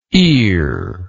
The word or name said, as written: earlike